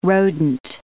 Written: rodent

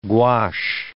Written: gouache

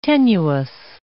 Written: tenuis

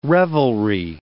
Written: revelry

